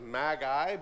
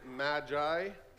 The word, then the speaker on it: Magi: This episode contains the story of the visit of the Magi, or it would be pronounced Magi, but that doesn't sound right in English, so we say Magi.